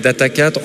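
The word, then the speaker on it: Data4: Donc moi, je prononce en fait Data 4, en fait, avec le... puisqu'on est très local, on dit Data Quattro, Data 4, Data Sphere.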